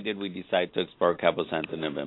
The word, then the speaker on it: cabozantinib: It's called cabozantinib. I'm probably not saying that right. – You're saying it correctly.